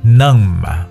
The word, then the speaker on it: numb: 在很早之前的节目当中，我跟大家有去说过，英文单词凡是以 n b 结尾的话呢，b 都不发音。 OK，我们说过，比如像爬山的爬 climb，对不对？这个啊，麻木的 numb，还有坟墓 tomb，还有梳子 comb 等等，凡是以 m b 结尾的词呢，b 都是哑音状态。